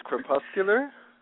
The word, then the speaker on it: crepuscular: Well, I believe it's pronounced crepuscular.